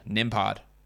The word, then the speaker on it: Nembhard: How do you say that name? Nembhard.